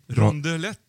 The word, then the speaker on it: Rondelet: Och enligt den franska naturforskaren Guillaume Rondelet.